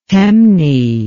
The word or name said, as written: Temne